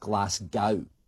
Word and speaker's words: Glasgow: It's also not pronounced Glasgow. Basically, that first noise is not glass. How is it pronounced? Well, that's negotiable. So, the most common way you'll hear it pronounced is Glasgow.